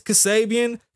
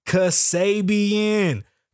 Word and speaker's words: Kasabian: You know why I start the show with "It's Kasabian"? Because they don't say my name right. It's Kasabian. Kasabian Lavoe.